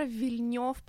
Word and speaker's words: Вильнёв: Короче, я влюблена в режиссера Вильнёв, правильно ли я делаю ударение? – Всё верно.